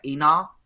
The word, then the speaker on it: Enoch: I get Enoch. How do you say that? E-N-O-C-H. – Enoch.